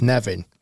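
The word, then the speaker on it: Nefyn: We went to Nevin Golf Club, kind of spelled almost like Neffin, but the locals pronounce it as Nevin.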